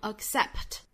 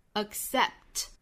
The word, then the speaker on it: accept: 我们不是读成 accept 而是 accept